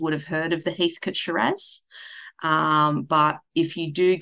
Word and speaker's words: Heathcote: And then actually my favorite region for actual drinking of wine is directly north of Melbourne, and that's because it is an area called Heathcote, spelt Heathcote, but we pronounce it Heathcote, and it rivals the Barossa Valley for really big, bold, dark reds.